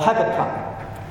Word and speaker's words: Habakkuk: Habakkuk said, or Habakkuk, depends how you pronounce it.